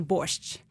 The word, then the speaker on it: borscht: So in Ukrainian, we usually say borscht.